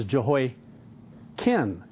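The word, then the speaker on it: Jehoiachin: And what even becomes a little more confusing later on is Jehoiakin. It's C-H-I-N, but it's pronounced Kin.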